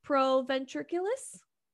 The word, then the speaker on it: proventriculus: Proventriculus. – Proventriculus. Hopefully I said that right. – I think so.